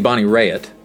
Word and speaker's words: Bonnie Raitt: It's supposed to be Bonnie Raitt.